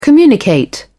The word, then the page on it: communicate: British English